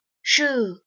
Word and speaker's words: shoe: 那么在朗读的句子当中呢，有鞋子 shoes 这个单词，但是有很多同学都读的不是很准确，所以今天呢，我们来讲一下鞋子这个单词应该读成是 shoe shoe，其中包含了一个长元音 u shoe。